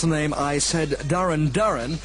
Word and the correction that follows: Duran Duran: and Planet Earth at number 26, when, of course, it should be, as everybody knows, Duran Duran.